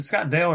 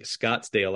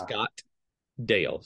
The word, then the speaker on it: Scottdale: And that's another thing a lot of people get wrong is, you know, a lot of people think Scottsdale, like Arizona, but yeah, it's Scottdale. So there's Scottdale.